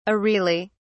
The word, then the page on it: areolae: British English